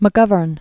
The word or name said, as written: McGovern